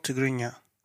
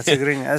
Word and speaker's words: Tigrinya: So it's called Tigrinya. – I don't know how to say it. Yeah, Tigrinya.